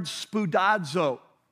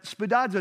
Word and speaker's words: spoudazo: The word in Greek is the word spoudazo. I'm going to spell it for you because a lot of you like to write these things down. But spoudazo is S-P-O-U-D-A-Z-O, spoudazo.